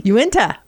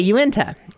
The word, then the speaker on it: Uinta: Uinta. – Okay, Uinta.